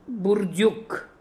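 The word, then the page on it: Boordook: Russian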